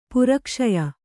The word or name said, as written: ಪುರಕ್ಷಯ